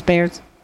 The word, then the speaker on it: Baird's: This little post here, you just posted it on June 26th, was Mrs. Bard's bread. – Ms. Baird's? – Baird's, Bard's? How do you pronounce Baird's. It? – Baird. – Baird? – Ms. Baird's.